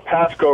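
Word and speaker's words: Pascoag: Pescoag, Rhode Island. I'm mispronouncing it, aren't I? – Hey, Tom. It's Pasco, Rhode Island, but good okay.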